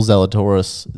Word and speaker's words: Zalatoris: So you mentioned before that Will Zalatoris, is that how you say it? – Yep.